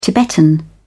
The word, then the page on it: tibetan: British English